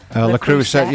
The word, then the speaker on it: Le Creuset: Haven't you got one of those, I can't pronounce it very well, I apologise, Le Creuset? – Le Creuset, yeah, pots.